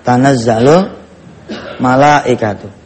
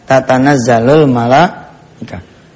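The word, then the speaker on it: tanazzalul malaikatu (تنزل الملائكة): Dulu saya salah baca tuh. Inna anzalnahu fi lailatul qadr wa ma adraka ma lailatul qadri khairum min al fisyar tanazzalul malaikatu. Dulu saya bacanya tatanazzalul malaikat.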